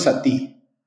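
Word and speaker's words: Satí: No, eh, eh, ¿cómo se pronuncia? ¿Sati o Sati? – Nosotros siempre le hemos dicho Sati. – Ah, Sati, así con, con tilde en la I.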